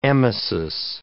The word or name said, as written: emesis